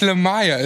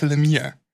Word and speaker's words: Lemire: Ich habe gehört, dass es nicht Jeff Lemire ist, sondern Jeff Lemire. Okay. Ja, ich hätte gedacht, bei L-E-M-I-R-E e wird es Lemire ausgesprochen, aber zur kleinen Berichtigung nochmal.